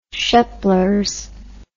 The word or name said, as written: sheplers